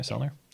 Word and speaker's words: Sellner: Sellner.